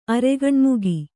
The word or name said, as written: ಅರೆಗಣ್ಮುಗಿ